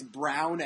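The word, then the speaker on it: Brawn: The first race, and it could end up that this Ross Brown, it's Brown F1 is the name, but it's spelled Braun, but it's pronounced Brown, I guess.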